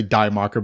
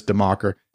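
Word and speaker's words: Die Macher: I always say Die Marker, but it's like he slapped me once and said it's Demarker.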